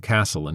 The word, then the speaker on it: castle: At her age, when I was first exposed to chess, I called the knights horsies and pronounced the T in castle until I was 7.